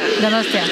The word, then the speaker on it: Donostia: Donostia.